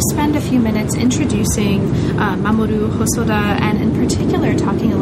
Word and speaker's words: Mamoru Hosoda: And this is the figure of Mamoru Hosoda. Am I pronouncing that sort of not entirely wrong? – That's just right. Just perfect.